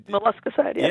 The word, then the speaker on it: molluscicide: and that is molluscicide. I'm probably butchered it. Molluscicide. – Molluscicide, yeah. – Yeah, molluscicide.